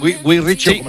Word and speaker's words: Guy Ritchie: Lo he dicho bien, Guy Ritchie. – Sí, exactamente.